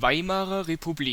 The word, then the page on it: Weimarer Republik: ˈvaɪmaʁɐ ʁepuˈbliːk; German